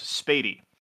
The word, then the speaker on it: Spoede: And also, for some reason, the street spelled Spoede is pronounced Spady.